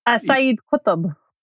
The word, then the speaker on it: Sayyid Qutb: Sayyid Qutb.